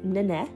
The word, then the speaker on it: Nenek: So on one side, it's Nenek. So it's spelled Nenek, N-E-N-E-K, but it says, the K is there and not, like a ghost. Start saying the K, but stop suddenly before any sound comes out.